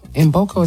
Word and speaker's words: Mboko: Mboko is how you say it. Victoria Mboko is M. It's K-M-B-O-K-O.